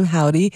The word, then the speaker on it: HOVVDY: Howdy, although it's spelled H-O-V-V-D-Y, but they go by the name Howdy.